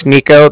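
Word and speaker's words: Niko: Yeah, like I said, my name is Nico. That's N-I-K-O. And then the Bank of Cardiff is spelled C-A-R-D-I-F-F, as in Frank Frank.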